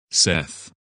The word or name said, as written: Seth